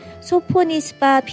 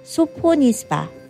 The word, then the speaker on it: Sophonisba: 첫 번째는 소포니스바 피노누아라는 레드 와인인데요. 스펠링 한번 알려드릴까요? 소포니스바 S-O-P-H-O-N-I-S-B-A 피노누아.